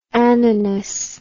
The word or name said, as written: ananas